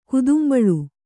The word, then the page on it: ಗುಧಂಬುಳು: kannada